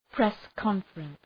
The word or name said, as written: press conference